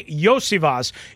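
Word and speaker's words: Iosivas: How do you say it? Zaveas? I was watching him the other day. Is it Zaveas? – Yosivas. – Andre Yosivas.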